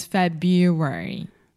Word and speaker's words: February: But February is a very strange word because there are there's that R right after Feb, but we do not pronounce that. – so we do not say February. – It would be February, but it's February. – February. – Yeah. – Yeah. So, another one of those words in English that contains a lot of R sounds that would be really difficult to pronounce. So, we just kind of are lazy and we don't say the R.